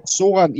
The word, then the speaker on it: Zoran: Soran, ja, man siger jo Z på dansk, men det lyd, som vi producerer, når vi siger det bogstav i Bosnien, det kommer slet ikke ud i dansk, så man siger bare Z.